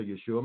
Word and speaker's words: Yeshua: If you don't believe that um, Jesus Christ or Yeshua Messiah, that's how you say his name in Hebrew,